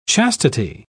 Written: chastity

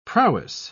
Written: prowess

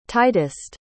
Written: tightest

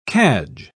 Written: cadge

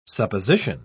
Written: supposition

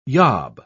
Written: yob

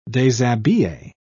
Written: deshabille

